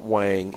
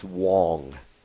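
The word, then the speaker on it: Huang: I'm told I pronounce it wrong. We've been pronouncing it wrong, Barry. It's not Wang. It's Wong. It's Jensen Wong.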